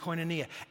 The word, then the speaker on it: koinonia: This Bible word for fellowship is the Greek word koinonia. That's how it's pronounced in Greek, koinonia.